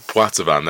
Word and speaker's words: Poitevin: Poitevin, there we go.